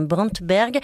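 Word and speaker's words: Brantenberg: Et Gerd, si je prononce bien, Brantberg, Les filles d'Egeli, c'est la Norvégienne. C'est bien ça.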